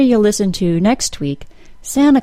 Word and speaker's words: Santa: In the name Santa, we've got a letter T that follows an N sound and comes before a vowel sound. If you've been studying your T sound allophones, you might recognize that this is a place that the T sound can be dropped. Therefore, you'll often hear Santa called Santa Claus. Santa is a perfectly acceptable pronunciation.